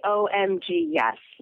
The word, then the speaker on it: OMGYES: You're the co-founder of OMGYES. Is that how it's pronounced? – Yeah, you can say that, or you can say OMGYES, like, oh my God, yes.